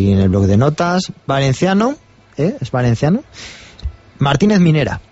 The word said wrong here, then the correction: Munuera: Munuera, perdón que se me... – Da igual. – Munuera.